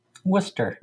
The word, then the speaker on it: Worcester: Well, and Leominster or Leominster is right outside of the big city in Central Massachusetts, which is pronounced Worcester. – Worcester, which would have pronounced over here.